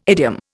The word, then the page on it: idiom: Indian